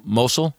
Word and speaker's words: Mosul: Mosul.